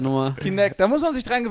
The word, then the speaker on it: Kinect: Unter anderem natürlich das ehemalige Projekt Natal, was jetzt Kinetic heißt. – Falsch, nee. du hast das schon falsch Mäh. gesagt. Noob. Das habe ich falsch gesagt. Kintech. – Kintech. – Kinect. Kinect, verdammt. Kinect.